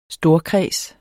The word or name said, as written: storkreds